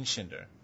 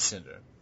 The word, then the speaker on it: Schinder: Keep on speculating, Steven Schinder. Oh, I'm sorry. Rhymes with Cinder, so I shall get that right in the future. Steven Cinder.